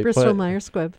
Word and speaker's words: Bristol-Myers Squibb: For the same reason that they're, But the each company, Bristol-Myers Squibb is, I don't know if I'm saying that right. – Bristol-Myers Squibb.